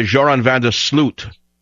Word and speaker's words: Joran van der Sloot: Now, the correct Dutch for Joran van der Sloot. That's the correct Dutch English pronunciation of van der Sloot.